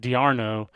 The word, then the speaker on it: d'Arnaud: But Kyle Drabek, Michael Taylor, Travis d'Arnaud, I guess it's actually pronounced d'Arnaud, Zach Collier, J.A.